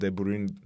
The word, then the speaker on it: De Bruyne: Ona da söyleyin isterseniz De Bruyne diye okunuyor senin adın diye.